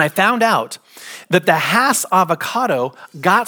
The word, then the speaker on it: Hass: A man by the name of Rudolph Hass, H-A-S-S, pronounced Hass like pass. All right, just so you know, if you've been pronouncing it Haas, you are incorrect. Because I've done my research and they're very, very particular. It is the Hass avocado, like pass.